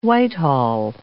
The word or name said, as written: Whitehall